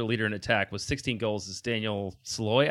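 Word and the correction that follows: Sallói: Shallowy, I believe is how you pronounce it.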